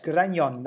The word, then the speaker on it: Grañón: That's spelled G-R-A-N-O-N. And the first N has kind of the little moustache hanging over it. I don't actually know the name of that symbol in Spanish, but yes, Grañón, that's how it's said.